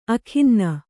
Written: ಅಖಿನ್ನ

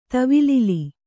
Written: ದೇವಲಾಲಿ

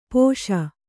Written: ಪಾಶಿ

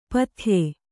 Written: ಪತ್ಯ

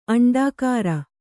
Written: ಅಂಡಾಕಾರ

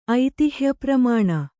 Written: ಐತಿಹ್ಯಪ್ರಮಾಣ

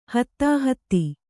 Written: ಹತ್ತಾಹತ್ತಿ

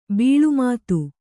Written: ಬೆಲ್ಮತ್ತಿ

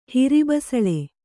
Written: ಹಿರಿಬಸಳೆ

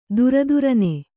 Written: ಥರಥರನೆ

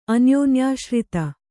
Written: ಅನ್ಯೋನ್ಯಾಶ್ರಿತ

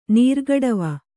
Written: ನೀರ್ಗಡವ

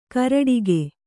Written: ಕೇರಡಿಕೆ

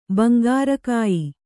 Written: ಬಂಗಾರಕಾಯಿ

